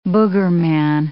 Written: boogerman

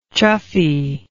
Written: chuffy

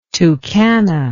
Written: Tucana

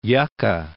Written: Yucca House National Monument

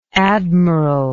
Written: admiralship

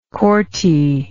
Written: corti

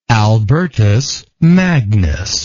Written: albertist

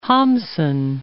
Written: Hamsun

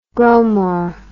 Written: growmore

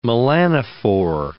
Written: melanophore

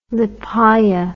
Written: Lepaya

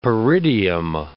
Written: peridium

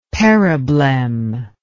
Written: periblem

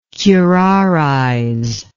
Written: curarize

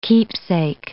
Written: keepsake

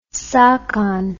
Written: sakon

